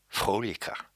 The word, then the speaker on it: fröhlich: And I must say, I do enjoy this word, fröhlicher. I guess it would be fröhlicher, because the O has an umlaut over it. – Fröhlich. – So there's the correct pronunciation.